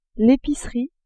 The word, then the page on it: epicerie: female